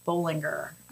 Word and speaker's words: Bollinger: My last name is spelled S-C-H-W-A-R-Z. So I'm on Facebook as Karen Schwarz. And on Instagram, I'm at Schwarz Bollinger. The Bollinger is B-O-L-L-I-N-G-E-R.